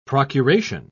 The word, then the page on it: procuration: British English